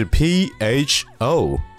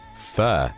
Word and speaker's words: pho: 但是我想说的并不是越南河粉的味道如何，而是这个越南河粉的英文一定要去说对，它的拼写是 P H O。所以说，如果看拼写的话，这个词很容易让我们读成 pho，对不对？但是事实上，它的正确的发音是 f，f。